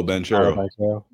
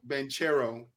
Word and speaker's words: Banchero: I don't know how to say this guy's name, the guy from the Magic, Paolo Banchero. – Banchero.